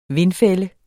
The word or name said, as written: vindfælde